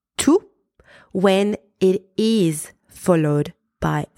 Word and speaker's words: tous: I am pronouncing T-O-U-S as tout because there is a noun after tout.